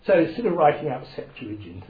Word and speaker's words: Septuagint: S-E-P, like in September. S-E-P-T-U-A-G-I-N-T. G-I-N-T.